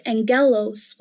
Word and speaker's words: angelos: In fact, the word angel comes from the Greek word angelos with a hard G, which means messenger.